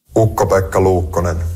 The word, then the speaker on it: Ukko-Pekka Luukkonen: C'est le Finlandais Ukko-Pekka Luukkonen qui va être le gardien, ou comme il le prononce lui-même... – Ukko-Pekka Luukkonen.